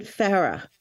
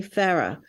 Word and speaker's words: Farrar: In the UK, they pronounce it Farrah. So Jeremy Farrah.